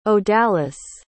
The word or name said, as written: odalis